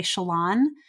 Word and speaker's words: Shallan: So Brandon is very clear that how he pronounces names and how the characters would pronounce their names are often different. And so he is not very picky about it. So I won't be picky about it. I personally say Shallan.